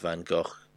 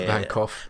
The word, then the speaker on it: Van Gogh: It's actually quite interesting because although he mispronounces it to what is largely accepted in England, it's actually a much closer to the proper pronunciation of Van Gogh than what we actually say. A lot of people are going to say Van Gogh, which is massively not even close to what the Dutch say.